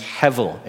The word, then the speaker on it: hevel: The Hebrew word is hevel. It's H-E-B-E-L, but it's pronounced hevel, H-E-B-E-L, hevel.